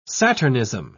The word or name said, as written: saturnism